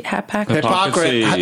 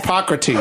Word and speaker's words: Hippocrates: Hippocrates. – Yes. – Hippocrates.